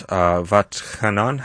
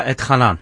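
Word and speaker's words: Va'etchanan: And for those that want to follow along, we're in Deuteronomy 3, in a portion, hard for me to pronounce, Vatchanan. How do you do it, Rabbi? – Yeah, you said it right. Okay. Vatchanan. Okay. That's correct.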